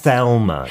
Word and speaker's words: Thelma: 예, 그런데 어, 영국식 버전으로 아까 어떻게 이야기를 해주셨죠? Thelma. TH Thelma. 발음이니까 Thelma and Louise.